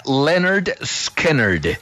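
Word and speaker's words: Lynyrd Skynyrd: En 1973, Leonard Skinner, que es un nombre muy jodido de pronunciar, a mí la verdad me da dificultad, le puso ese nombre a ese álbum. Se pronuncia Leonard Skinner.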